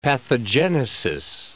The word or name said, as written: pathogenesis